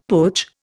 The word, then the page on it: putsch: Indian